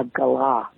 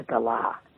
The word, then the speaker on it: Galah: I did Thorn Birds, and I think there's a Galah bird, not a Gullah bird, a Galah. And any native-born Australian will wince if you say it wrongly.